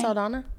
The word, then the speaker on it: Saldana: Saldana? – Yes.